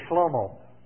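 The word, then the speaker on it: Shlomo (שלמה): And Solomon is, how you say it in Hebrew, Shlomo.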